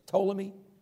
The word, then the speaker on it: Ptolemy: And then Ptolemy, if I'm pronouncing it right, Ptolemy, he took control of Egypt and the southern portion. What was it? Ptolemy? Yeah, yeah.